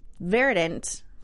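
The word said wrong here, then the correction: Verdiant: Did I say that right? Oh my God. Verdient. – Verdient.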